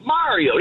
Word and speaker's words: Mario: Okay, well, the video game, he says, even in the thing when he does his little, it's me, Mario. He doesn't say it's me, Mario. – Right. – It's not Mary with an O.